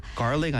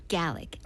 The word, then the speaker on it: Gallic: Garlic 아니고 Gallic? – Gallic. Or Gaelic or Gallic.